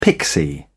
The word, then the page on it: pixie: British English